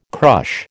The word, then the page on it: crush: American English